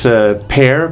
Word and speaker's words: Per: Well, my name is Pear, P-E-R, pronounced like the fruit.